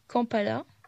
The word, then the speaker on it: Kampala: Sa capitale c'est Kampala. Je pense que ça se prononce comme ça, Exact.